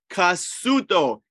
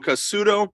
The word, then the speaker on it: Cassuto: Umberto Casuto, Cassuto. I think that's how you say his name, Umberto Casuto, who, it's tough stuff, but if you really want to do some damage, you get out Casuto.